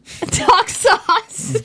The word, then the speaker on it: Daxos: Doxos. – Doxos. D-A-X-O-S. Doxos. Zorro Zoan Doxos.